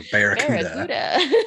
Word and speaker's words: Barracuda: Barracuda? I'm saying that wrong, I think. Barracuda. – Like the song, Barracuda.